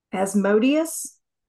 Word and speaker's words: Asmodeus: Asmodeus.